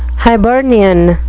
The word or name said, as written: hibernian